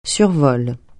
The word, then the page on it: survol: French